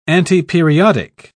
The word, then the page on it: antiperiodic: British English